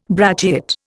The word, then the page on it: brachiate: Indian